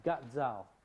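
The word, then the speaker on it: ca dao: Sadao on the handout here, C-A-D-A-O. I'm told it's pronounced Gad Zhao.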